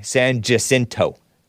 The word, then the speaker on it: San Jacinto: San Jacinto, County Sheriff Greg Capers told local media. I go between pronouncing it the Mexican Hispanic way, Honduran way, the Hispanic way versus the white way, San Jacinto.